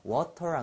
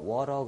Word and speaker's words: water: conceited 자 또는 부드럽게 발음하면 conceited 자 요것은 그 t 앞에 모음이 오고 t 뒤에 모음 즉 모음과 모음 사이에 t가 오면은 이것이좀 부드러운 알 발음 비슷한 현상을 일으킵니다. 그래서 water 안 그러고 water 그러잖아요. 그래서 conceited 요것도 conceited 자, 이렇게 부드럽게 하는 것은 사실 미국식 발음이고 영국에서는 water 이렇게 하지 않고 water 이렇게 t 발음을 정확히 해주는 그러한 식으로 합니다.